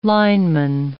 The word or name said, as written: lineman